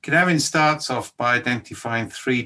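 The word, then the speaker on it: Cynefin: First of all, Canavin is a Welsh word, so it's pronounced Canavin, yeah?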